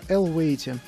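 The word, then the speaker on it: Eluveitie: Группа со сложно сочиненным названием Elevate. Так это произносится, Elevate.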